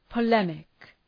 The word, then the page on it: polemic: pə’lemık